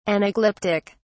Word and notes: anaglyptic: British English

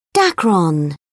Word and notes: dacron: British English

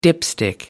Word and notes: dipstick: American English

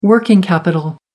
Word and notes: working capital: American English

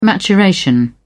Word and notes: maturation: British English

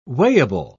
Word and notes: weighable: British English

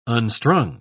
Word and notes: unstrung: British English

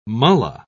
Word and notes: mulla: British English

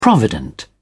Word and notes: provident: British English

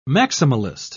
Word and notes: maximalist: British English

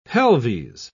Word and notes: pelves: British English

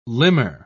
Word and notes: limmer: British English

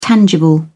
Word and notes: tangible: British English

